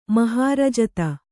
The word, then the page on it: ಮಹಾರಜತ: mahārajata